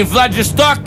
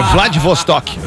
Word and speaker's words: Vladivostok: Após chegar em casa, em Vladivostok, o homem foi novamente assaltado. – Ah, não, cara! É Vladivostok.